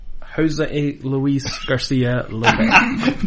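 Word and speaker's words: José Luis García López: What amazes me, and let me see if I could, George Lopez Garcia. – Close enough. – That can't be right. – Yeah, I know. Please say his name. – Jose Luis Garcia Lopez.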